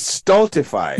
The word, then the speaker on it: stultify: So I think I may have mispronounced it. It's stultify.